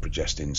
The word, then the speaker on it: progestins: So I said proglastins, and I actually meant progestins, so I apologize for pronouncing that totally incorrectly.